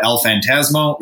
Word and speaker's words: El Fantasmo: If you do follow New Japan, they just kicked out El Fantasmo, right? Did I say that right? I did it right. You did.